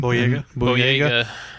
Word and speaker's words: Boyega: Yeah, and then John Boyega, I think that's how you say his name. – Boyega.